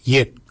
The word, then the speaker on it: yed: Nanak is spelled N-A-N-G-A-G. Nanak. Nanak is your neck. Chin. We say yet. Yet for chin. Yet is spelled Y-E-D.